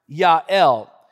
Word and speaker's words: Yael: Now, I know it's spelled jail, okay? But the original language, it's pronounced Yael.